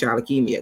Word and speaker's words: Shalakemia: You know, and I knew people weren't going to know how to say it. So that's why at the end of every video, I'm like, and my name is Shalakemia, because I need people.